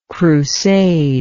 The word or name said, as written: crusader